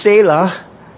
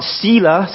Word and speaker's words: Selah: So I believe that the word Selah is pronounced Selah, not Selah. Sometimes we hear it pronounced as Selah.